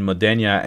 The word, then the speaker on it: Modena: Modena, whatever.